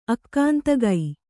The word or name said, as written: ಆಗಂತುಕ